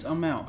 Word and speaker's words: Amell: There's no R in there. – I always think there is. – No, it's Amell.